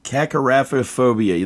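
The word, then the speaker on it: kakorrhaphiophobia: Cacaraphiaphobia. That's right.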